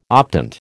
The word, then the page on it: optant: American English